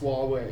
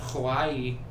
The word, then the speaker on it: Huawei: Yeah, it's pronounced Huawei. – Because it's Chinese? – Yeah. – I've been calling it Hawaii. – No, no, it's Huawei.